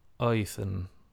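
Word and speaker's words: Auðn: And she says it's pronounced Oithan.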